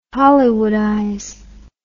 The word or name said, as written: hollywoodize